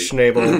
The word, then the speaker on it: Schnabel: Do you think that Marco Schneisbell ever... – Schneisbell. – How you say his name. – Might as well be. – Schnabel. – Schnabel was ever like, Marco Schnabel!